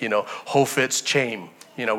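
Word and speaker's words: Chofetz Chaim: There's a lot of different pronunciations for his name, but I believe the correct pronunciation of his name is Kofet Chaim.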